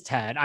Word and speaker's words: Thad: Give it up for Thad DeBrock. – Oh, my God. Well, you know, it's funny. You know, my name's actually Tad, but it's spelled T-H-A-D. – Oh, so it is Tad. I mean, I've been calling you Thad and Tad. – It's spelled T-H-A-D.